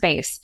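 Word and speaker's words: Béis: Funny enough, I mean, I was one of the people who pronounced it incorrectly for a long time until I was watching Shay Mitchell give the explanation. And my base bag. I'm like, what? Huh? What? Oh, okay. Yeah. It's pronounced base.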